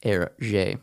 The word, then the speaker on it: Hergé: He created the pseudonym for Hergé by flipping his initials and then using the French pronunciation for them. So instead of G-R, he flipped him to R-G.